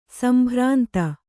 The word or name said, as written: ಸಂಭ್ರಾಂತಿ